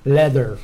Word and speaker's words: leather: Comment on prononce leader ? – Leather, voilà. – Leather. Avec la langue qui sort de la bouche.